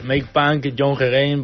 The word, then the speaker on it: Make Punk Young Again: No sé si lo he pronunciado bien, ¿eh? – Sí, pues bueno, como llevamos diciendo un tiempo, durante toda la entrevista, es, pues al final es Make Punk Young Again, para que los chavales vuelvan a ser punkies, vuelvan a eso y dejen, bueno, sin más, dejen lo que quieran, vamos.